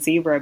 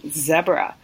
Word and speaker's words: zebra: I'm originally Kenyan, and I must say with that, my boyfriend would kill me if I didn't give myself a hard time for saying zebra, because they're actually pronounced zebra.